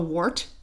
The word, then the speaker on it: wort: And eventually it's resulting in this fermented liquid that's called a wort. I think that's how you pronounce it. – Yep.